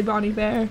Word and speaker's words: Bon Iver: Yeah, I just can't bring myself to say Bon Iver. – Yeah, I know. Although I'm pretty sure that's how you say it, but it's just not.